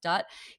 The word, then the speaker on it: Dut: I just interviewed Salva Dutt, D-U-T is how you spell it, and that pronounced Dutt.